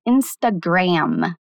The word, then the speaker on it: Instagram: Em inglês, a pronúncia é Instagram. Notou que o fim é mais marcado? As palavras em inglês que terminam com M têm que ser mais marcadas para dar uma diferenciada do N.